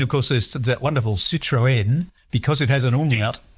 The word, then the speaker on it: Citroën: But yes, pronounced Citroën.